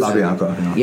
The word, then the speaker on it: LaBianca: Is that how you say it? – Murders. LaBianca.